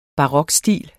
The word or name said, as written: barokstil